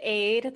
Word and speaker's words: Aid: So my name's Aid. That's how you pronounce it. Sometimes people don't know. It's pronounced like lemonade.